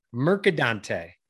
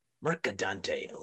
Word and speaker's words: Mercadante: You forgot the C's, but you got it. You still got it closer than 95% of the people. So that's great. – Brilliant. So pronounce it like it's meant to be said. – Mercadante. – Mercadante. Love it.